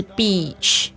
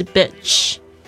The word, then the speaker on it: beach: 这个如果你念成 bitch 的话呢，就会变成骂人的话，所以呢，就是要注意一下，是 beach 不是 bitch。